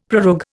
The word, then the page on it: prorogue: Indian